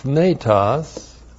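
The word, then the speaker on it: thnetos (θνητός): And this flesh here is modified by the adjective thnetos. T-H-N long E-T-O-S.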